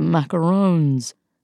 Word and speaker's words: macarons: Ah, the macarons. By the way, kudos for not pronouncing it as if it rhymes with raccoons.